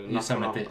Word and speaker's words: Yosemite: Nu vet jag inte hur man uttalar det här egentligen, men är det Yosemite? Alltså, Yosemite, ja. Yosemite. Det går att uttala det på flera olika sätt, men Yosemite är...